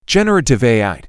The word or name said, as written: Generative AI